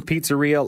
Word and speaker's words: Pizzarrhea: Before that, we heard a couple of tracks from Pizzarrhea, and I'm pronouncing Pizzarrhea like diarrhea because that's how it's spelled Pizzarrhea.